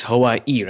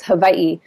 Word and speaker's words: Hawaii: It's Hawaii, right? With the stress towards the end and not Hawaii. – It is. It's actually, if you're saying the really correct pronunciation, the W sounds like a V, so it's Hawaii.